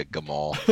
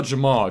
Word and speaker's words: Jamal: He's going to change Jamal Adams' first name to Gamal. – It's like Job and Gamal. No, it's still Jamal. It's a soft G.